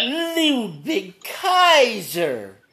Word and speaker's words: Ludwig Kaiser: Is that how you say it? – Yep. Well, you got to say it right. Ludwig Kaiser.